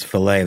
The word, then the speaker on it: fillet: Because that's how the British pronounce fillet. They pronounce it fillet. – Really? – They do a hard T sound at the end of it.